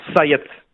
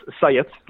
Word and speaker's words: Xhaët: It's Syatt. It's really easy. You're right. When you see it on paper, it looks difficult. But to pronounce it is just Syatt. So, Dennis Syatt.